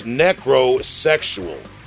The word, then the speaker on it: Necrosexual: And well, I have to stop you for a second, Big Will, because you actually mispronounced my name. It's the Necro Sexual. You got to get the falsetto.